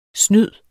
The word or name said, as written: snyd